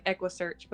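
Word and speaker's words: EquuSearch: So I don't really know how to say this, but Texas Equisearch, I want to say. – Yeah, it's like Equisearch, but it doesn't matter.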